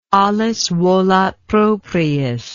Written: alis volat propriis

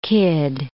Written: Kidd

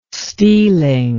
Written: stealing

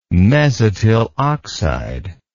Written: mesityloxide

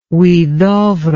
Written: Huidobro

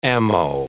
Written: ammo

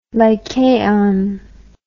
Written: lycaon

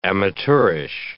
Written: amateurish

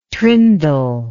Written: trindle

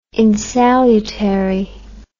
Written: insalutary

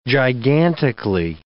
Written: gigantically

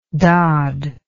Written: dodd

